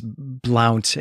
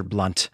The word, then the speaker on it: Blount: Yeah, I think also, Brent, you pronounced the name of this character as Blount, and it is spelled that way, as in like Count. But I think that this is probably meant to be pronounced as Mr. Blunt.